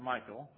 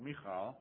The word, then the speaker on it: Michal: It's not Michael, but Michal, maybe.